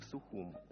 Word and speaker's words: Сухум: Как-то ее отец возвращался на поезде из Москвы в Сухум. Именно так, в мужском роде, абхазы пишут и произносят название своей столицы.